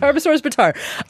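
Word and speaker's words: Tarbosaurus bataar: So let's talk a little bit about this Tarbosaurus batar. Did I say that right? Okay. – You did. – Okay, you did. – Tarbosaurus batar.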